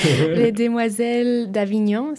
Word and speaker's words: Les Demoiselles d'Avignon: Les Demoiselles d'Avignon, so the Young Ladies of Avignon, not to be confused with the city in France.